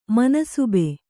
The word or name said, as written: ಮನ್ಸಬ್